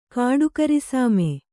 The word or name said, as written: ಕಾಡುಕರಿಸಾಮೆ